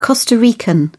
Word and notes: costa rican: British English

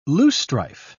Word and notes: loosestrife: British English

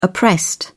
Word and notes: oppressed: British English

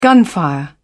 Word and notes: gunfire: British English

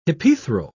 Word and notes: hypethral: British English

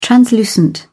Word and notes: translucent: British English

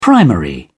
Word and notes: primary: British English

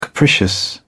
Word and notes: capricious: British English

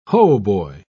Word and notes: hautboy: British English

